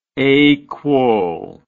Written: aquo

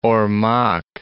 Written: Ormoc Bay

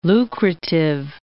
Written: lucrative

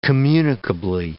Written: communicably

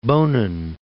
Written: Bonin Islands

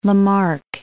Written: Lamarck

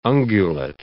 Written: ungulate

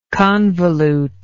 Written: convolutely

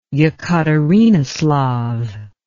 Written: Yekaterinoslav

